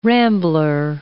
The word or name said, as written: rambler